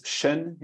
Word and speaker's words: Chene: It's Chen in French, by the way, the pronunciation, but you yes. can pronounce it another way yes. if you want.